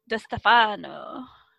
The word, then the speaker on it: Di Stefano: Okay, it's on Tina Gallows and Gabrielle Di Stefano. Okay, you gotta say it like Italian. It's like Di Stefano.